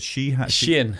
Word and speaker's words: Shein: Shein.